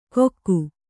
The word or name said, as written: ಕೊಕ್ಕು